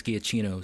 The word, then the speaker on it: Giacchino: Like, I was thinking, maybe they could tap somebody if they wanted somebody new, like Michael Giacchino or I think it's Giacchino is the correct pronunciation of his name, who scored Rogue One and Mission Impossible 3, and he also did the Star Trek films.